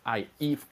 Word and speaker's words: Ive: Johnny Eve 或者係 Johnny Ive 都可以。 – OK。 – 因為我聽翻好多美國嗰邊嘅科技網站嘅 YouTube 啦。咁都係發音係 Johnny Ive 嘅，係啦，但係亞洲呢邊嘅，即係甚至乎可能台灣嗰邊咧，都會發音都係讀 Ive 嘅，係啦，咁呢個冇問題嘅，